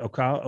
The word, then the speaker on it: Ocala: Is that how you pronounce it? Ocala, O-C-A-L-A? – Yeah, I've always said it Ocala.